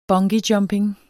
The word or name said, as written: bungeejumping